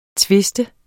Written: twiste

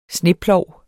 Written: sneplov

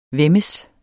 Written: væmmes

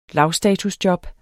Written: lavstatusjob

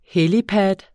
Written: helipad

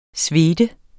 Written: svede

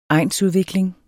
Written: egnsudvikling

